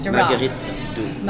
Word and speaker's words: Duras: It's based on a book by Marguerite Duras. Is that am I Duras. – Saying? – Duras. Marguerite Duras. – Yeah, I never say it right.